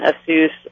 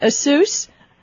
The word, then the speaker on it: Asus: Is that how you pronounce it, Asus? – Yes. – I've always called it Asus. I'm such a dork. – I think you can get away with that. I think they'll answer to either.